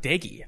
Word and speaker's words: Daigie: The fact that it's pronounced Daigie. I thought it was Doge for a long time, but then I was listening, so I was doing mostly all 22 where there's no like sound, but then I finally saw a broadcast play and they're like, Daigie going deep. I was like, wait, fuck, guy's name's Daigie.